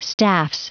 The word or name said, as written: staffs